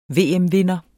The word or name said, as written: VM-vinder